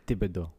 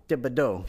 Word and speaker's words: Thibodaux: You know, I drove about 20 minutes away to my brother's house in Thibodaux, Louisiana, which is pronounced Thibodeaux. – Thibodeaux. Yeah.